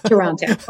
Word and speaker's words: Toronto: Yeah, Toronto. Although people that live here might say Toronto, but I like to say Toronto.